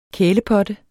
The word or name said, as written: kælepotte